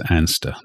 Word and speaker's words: Anster: I googled it and looked at the various details, and apparently it's known as Anster locally.